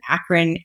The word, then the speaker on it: Akron: So obviously built at, we pronounce it Akron.